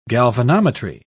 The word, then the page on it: galvanometry: British English